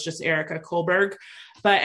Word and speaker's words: Kullberg: Kohlberg. I think I'm saying that right, but it's like Erica with a K. N, and then her last name is K-U-L-L-B-E-R-G.